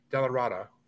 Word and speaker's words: DellaRatta: Our guest is Rick DellaRatta, and I hope I said that right.